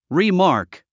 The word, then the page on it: remark: rimɑ’ː r k; リマーク